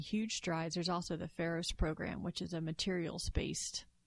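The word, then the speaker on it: Pharos: So it's Ferros. It's P-H. – Oh, okay. It's spelled different. – A-R-O-S, yeah.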